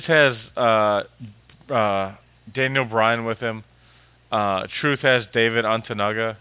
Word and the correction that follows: Otunga: or wait, Otunga. Sorry, I don't know why I always add an extra syllable for some reason with some of these names.